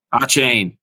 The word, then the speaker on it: Achane: But that Mostert and how do you say this guy's last name? Atkane? – Achane.